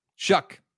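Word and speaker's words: Shough: And I think Tyler Shaw, is it Show or Shaw? – Shuck. – Shuck. – Yes. – That's not how I would have pronounced that name. – There's a GH in there. It is Tyler Shuck.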